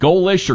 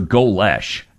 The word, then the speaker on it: Golesh: How do you pronounce his name? Is it Golish or Golesh? – I think it's Golish.